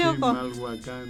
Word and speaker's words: Chimalhuacán: Chimalhuacán.